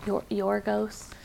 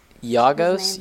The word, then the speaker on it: Yorgos: Yorgos, is that how you say it? – Yeah, Lanthimos is his last name. I think it's Yagos. Yagos Lanthimos.